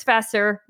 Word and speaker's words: Vasseur: His name is Chris Vasseur. I almost said Vasseur, but he told me not to pronounce it too Frenchly before we started.